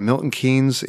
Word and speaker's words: Milton Keynes: I'm really excited to hear about Milton Keynes, England.